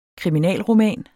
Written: kriminalroman

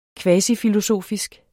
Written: kvasifilosofisk

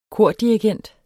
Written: kordirigent

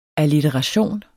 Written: allitteration

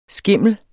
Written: skimmel

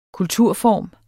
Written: kulturform

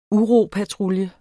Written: UROPATRULJEN

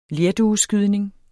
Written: lerdueskydning

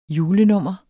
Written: julenummer

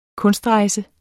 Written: kunstrejse

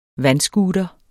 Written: vandscooter

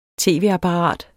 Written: tv-apparat

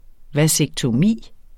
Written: vasektomi

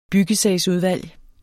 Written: byggesagsudvalg

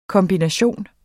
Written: kombination

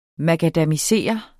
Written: makadamisere

